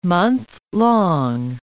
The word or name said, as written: monthlong